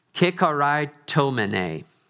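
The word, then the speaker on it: kikaritomene (κεχαριτωμένη): This word, "O favored one," is kikaritomene. Kikaritomene. Yeah, that's how you say it.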